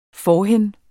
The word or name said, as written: forhen